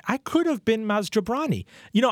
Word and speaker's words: Jobrani: That's the kind of person who, and they continue even when I say no, it's jabroni. They continue to mispronounce it because they.